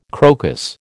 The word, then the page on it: crocus: American English